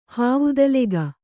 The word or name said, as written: ಹಾವುದಲೆಗ